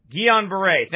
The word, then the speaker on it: Guillain-Barré: This Guillain-Barré syndrome or whatever it is. Guillain-Barré.